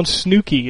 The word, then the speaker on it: Snooki: He's got a crush on Snooki.